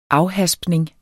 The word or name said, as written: afhaspning